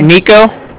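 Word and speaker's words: Niko: Nico, so that's N-I-K-O. I'm calling with the Bank of Cardiff, and that's spelled C-A-R, D as in dog, I-F-F as in Frank, so it's like car and then different.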